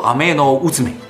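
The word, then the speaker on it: 天宇受売命: 天羽受麦命，读作阿麦诺乌兹美。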